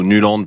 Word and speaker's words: Nuland: Je crois que un des gros duels de cette rencontre c'est Nyland Pickford parce que je trouve que Nyland ou Nuland, puisqu'il faut le prononcer comme ça, fait une compétition absolument incroyable.